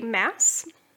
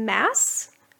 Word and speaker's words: Maas: And the ones that I found all pronounced her last name Mass instead of Moss. And none of them got corrected, so I think that's right.